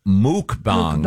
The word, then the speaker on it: mukbang: It's actually pronounced muk, yeah. – Is it yeah. – muk? – Mukbang. – Yep. Oh, I thought it was mukbang. – The pronouncer here is M-O-O-K.